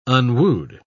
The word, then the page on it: unwooed: British English